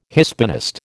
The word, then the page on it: Hispanist: American English